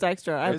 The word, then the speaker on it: Dykstra: I'm just wondering how that last name is actually pronounced. – I've asked her before. – Yeah. – Dykstra. It's Dykstra. – It's like Lenny, Dykstra.